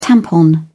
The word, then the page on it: tampon: British English